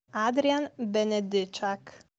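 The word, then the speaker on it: Adrian Benedyczak: Allora, siccome non è facilissimo per tutti pronunciarlo, noi abbiamo chiesto a un'insegnante polacca che vive in Italia, di dirci la corretta pronuncia del nome e cognome di Adrian Benedicak, che noi abbreviamo per comodità in Benek. Allora, sentiamo come si pronuncia, detto proprio da una polacca doc.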